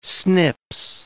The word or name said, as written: snips